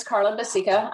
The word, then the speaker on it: Carla Bacica: So yes, my name is Carla Basica.